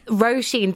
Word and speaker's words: Roisin: Roisin, the long O seems to be a real struggle for them, so it's always Rasheen, and that really grates on me because it's like Rasheen, Rasheen,